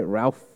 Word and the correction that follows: Ralph: Finn Finn Finnes. – Rafe. Oh, sorry. Yeah, Rafe Fiennes.